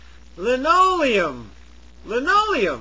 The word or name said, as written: linoleum